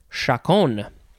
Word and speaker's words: chaconne: Okay, next is chacon. Chacon, chacon, chacon. It is spelled C-H-A-C-O-N-N-E.